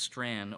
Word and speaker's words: Strachan: It looks like you would pronounce it Strachan, but it's Strand, Owen Strand.